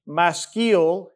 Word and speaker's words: Maskil: And then it says a Maskil is how you pronounce that.